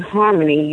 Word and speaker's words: Harminme: if you break down the first name Harmony, you'll see it's spelled H-R-M, which is harm, N-I-N, me.